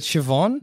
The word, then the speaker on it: Siobhan: Hey, how do you pronounce this? Is it Sean? S-I-O-B-H-A-N? – Siobhan, isn't it? – Is it Siobhan? – Yeah. – Okay, cool. I'm taking your word. – Siobhan. – Okay. This is Siobhan.